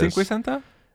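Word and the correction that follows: cincuenta: Cinquecenta. I was right. – Cinquenta. Cinquenta. – I had a lot of the right letters.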